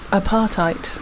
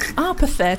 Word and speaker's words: apartheid: I used to mispronounce apartheid. – How? – I used to call it... – You thought it was a party? You're like, what's everybody complaining about? – I called it apathet.